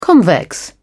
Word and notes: convex: British English

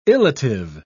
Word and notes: illative: British English

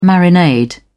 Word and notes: marinade: British English

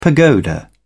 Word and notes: pagoda: British English

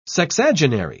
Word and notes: sexagenary: British English

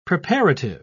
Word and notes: preparative: British English

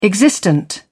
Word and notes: existent: British English

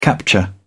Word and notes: capture: British English

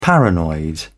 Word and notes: paranoid: British English

